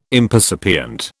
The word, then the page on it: impercipient: British English